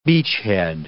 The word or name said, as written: beachhead